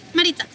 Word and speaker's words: Maritza: Maritza.